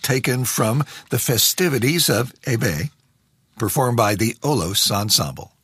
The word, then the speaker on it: Hebe: 926 at APR, I'm David Duff, and her name is, in English, often pronounced Hebe, in French, Hebe.